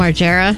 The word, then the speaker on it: Margera: Bam Margera. Never sure how to pronounce his last name. – Margera.